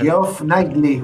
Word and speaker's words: Geoff Keighley: Geoff Keighley.